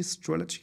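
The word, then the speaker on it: Strelecky: John Strelicky, Strelicky, glaube ich, wird da ausgesprochen.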